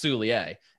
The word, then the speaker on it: Soulier: Liam Soulier's last name is supposed to be pronounced Soulier, and it has not been pronounced like that a single time on a broadcast this year. – Yeah, I think they're 0-6, because that's the media guide's very clear. It's yay at the end, but yeah.